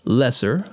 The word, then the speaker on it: Loesser: Yeah, it was written by Frank Lesser. I think that's how you would pronounce his name. It's spelled L-O-E-S-S-E-R.